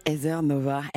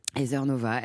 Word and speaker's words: Heather Nova: Heather Nova. Vous vouliez me tendre un piège? – Pas du tout, c'est que je ne sais pas comment ça se prononçait. – Heather Nova.